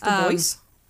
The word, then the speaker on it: Dubois: B-O-I-S. – Dubois? – It's probably French, so you don't probably pronounce the S.